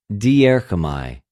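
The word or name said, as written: διέρχομαι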